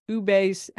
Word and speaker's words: ube: So one of your food trends is ube.